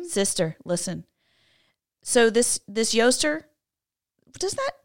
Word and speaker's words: Eostre: Okay, you say this word Yoster, but it's spelled E-O-S-T-R-E. – That looks like Easter. – Mm. But you technically would say it Yoster, but it looks like Easter.